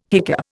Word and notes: hiccup: Indian

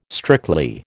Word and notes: strictly: American English

